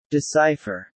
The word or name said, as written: decipher